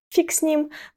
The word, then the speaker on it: фиг: Фраза «фиг с ним», при произношении которого вместо «г» на конце мы произносим «к», «фиг с ним», заставляет немцев покраснеть.